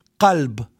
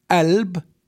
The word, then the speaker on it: qalb: So we have the word qalb, which means heart. In some areas, it's pronounced as alb. In some area with ka, kalb. And some area g, galb.